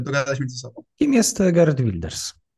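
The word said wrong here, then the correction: Geert Wilders: Hart Builders. Hart, dobrze. – E, tak, tak, bo H i G jest bardzo, bardzo oryginalnie wymawiane przez, przez, przez Holendrów.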